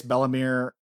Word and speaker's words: Bellemere: Bellemere? – Bellemere.